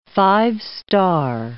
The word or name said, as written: five-star